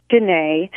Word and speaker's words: Dinneh: My middle name is Danae, so D-I-N-N-E-H.